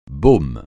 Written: baume

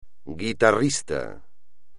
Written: guitarrista

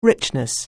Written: richness

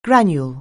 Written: granule

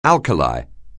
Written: alkali

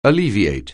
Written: alleviate

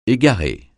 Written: égarer

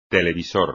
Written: televisor

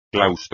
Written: claustro

